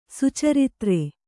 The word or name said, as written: ಸುಚರಿತ್ರ